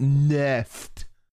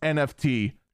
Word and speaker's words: NFT: but they're just memeing about the fact that The Verge pronounced it NEFT. It's NFT.